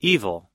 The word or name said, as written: evil